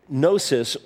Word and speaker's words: Gnosis: Gnostic, or to be in the know, Gnosis or gnosis, it's the silent G, means knowledge.